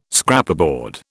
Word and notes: Scraperboard: British English

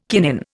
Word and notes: kinin: Indian

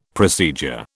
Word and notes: procedure: British English

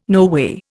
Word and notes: noway: Indian

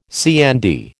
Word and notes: CND: American English

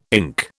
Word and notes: ink: British English

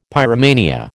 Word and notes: pyromania: American English

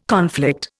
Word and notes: conflict: Indian

